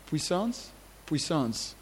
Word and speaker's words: puissance: What is power in French? Puissance? Oui.